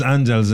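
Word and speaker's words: Àngels: Angela is Angels in Catalan.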